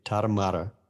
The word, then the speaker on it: Tarahumara: You think about the distance running, because you think about that, you know, Mexican, repeat after me. Tarahumara. – Tarahumara. – Tarahumara. – Okay. – It's spelled Tarahumara.